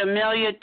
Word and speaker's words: Emilia: Well, you can find me over at Amelia T.